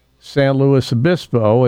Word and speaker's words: San Luis Obispo: Another one that is butchered is San Luis Obispo. I correct the gate agent and flight crew all the time. That is San Luis Obispo.